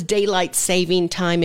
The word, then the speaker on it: daylight saving time: It's daylight saving time. There is no S. A lot of people add the S, so don't do that.